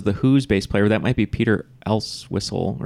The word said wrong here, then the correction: Entwistle: or whatever, however you say his name. Great. – Entwistle. – Entwistle, thank you.